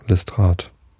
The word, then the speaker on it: Lestrade: Listrat oder ne, was sagt der Chat? Habe ich das jetzt richtig ausgesprochen? Ja, Listrat.